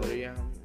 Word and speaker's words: Toriyama: I have Toriyama.